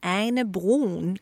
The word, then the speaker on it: Ane Brun: Because today we have the internationally acclaimed Norwegian board singer Ane Brun. Am I saying her name right? – Yeah, you're saying it very Danish-like.